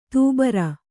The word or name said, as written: ದುಬಾರಿ